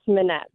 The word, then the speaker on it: Minette: Well, actually, you had it right the first time. It's Minette.